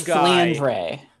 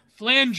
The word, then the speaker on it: Flandre: Flandra Scarlet, Aeon. – I'm pretty sure it's Flandre. – Flandre. – Flandre.